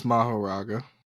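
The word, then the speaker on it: Mahoraga: So he called in Maguraga. I feel like I'm saying that wrong. – I think it's Maharaga. – Maharaga. – It's M-A-H-O-R-A-G-A. Yeah, so he called out Maharaga, and he was like, all right, we're going to have to do this ritual together.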